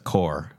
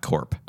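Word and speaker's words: corps: You know that it's core, not corp. – I first read it corpse and then I said core. Tank core.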